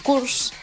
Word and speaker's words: course: A cures. – Sí. – Y se ve que pronuncié mal la palabra cures. – Ajá. – Porque no, un curso no se dice cures, se dice course.